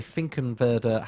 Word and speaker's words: Finkenwerder: Um, I want to say Finkenwerder Hamburg Airbus facility. How was that for pronunciations? – That's, Close, good. That's good.